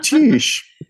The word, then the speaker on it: Tisch: And then how was Tisch? – Tisch. – Yeah. That's the proper pronunciation.